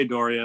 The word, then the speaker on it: D'Auria: Although I've had members of my own family pronounce it differently, so... Nobody knows, but I say Doria, and that's good enough for me.